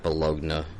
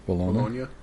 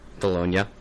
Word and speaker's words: Bologna: Battle of, I'm going to mispronounce this, Battle of Bologna scenario. – Bologna? – Bologna. Hey, that's much better, thank you.